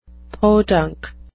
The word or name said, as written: podunk